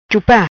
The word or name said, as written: Chupa